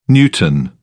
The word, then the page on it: newton: British English